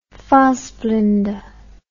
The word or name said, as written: Fassbinder